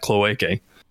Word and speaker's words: cloaca: We know that they have a biology at least somewhat similar to Solarians because they have cloacae. Or is that how you pronounce it? Cloacae? – Cloaca? – Cloaca. – Yeah.